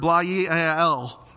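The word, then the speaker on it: bla'yael (בליעל): It's the Hebrew word bla'yael, and that is exactly how you pronounce it.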